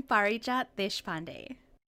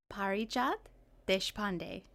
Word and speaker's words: Parijat Deshpande: I'm Parijat Deshpande. – Wow, say that one more time just for fun. – Sure. Parijat Deshpande, just as it's spelled.